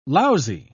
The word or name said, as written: lousy